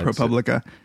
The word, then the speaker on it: ProPublica: I think ProPublica, yeah. – ProPublica, on algorithms used to determine recidivism rates in criminals.